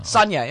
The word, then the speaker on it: Sonia: Sonia, yeah.